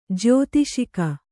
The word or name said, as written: ಜ್ಯೌತಿಷಿಕ